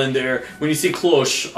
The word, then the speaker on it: Cloche: Well, if you noticed when they actually, put the title, when they put Cloche, and it's Cloche, not Cloches, because this is French. You don't pronounce, the last letter, but you do pronounce the last letter as Cité because it has the accent on the E.